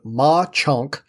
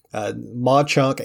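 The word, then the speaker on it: Mauch Chunk: actually two small communities in Pennsylvania called Mauchunk, and that is spelled M-A-U-C-H space C-H-U-N-K.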